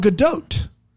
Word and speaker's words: Gadot: And apparently, someone sent us a video of her saying her own name when she hosted Saturday Night Live, and you pronounce the T at the end of it. Gal Gadot. – Gal Gadot. – Which I would never guess. – Okie doke. – I would have guessed Gadot.